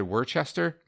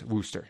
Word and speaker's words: Worcester: In the previous episode, when we were talking about Spooner, one of our previous episodes, I said Worcester. Apparently, that's incorrect. I'm not up on my East Coast pronunciation. It's Worcester, and I now know that.